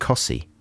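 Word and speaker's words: Costessey: The there's another place very near Wymondham that's spelt Costessi, and it's pronounced Cossie.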